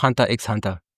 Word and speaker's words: Hunter x Hunter: Also heißt es doch Hunter X Hunter. – Ja, es heißt Hunter x Hunter.